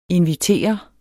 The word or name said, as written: INVITERES